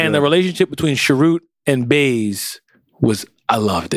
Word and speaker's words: Chirrut: Chirrut.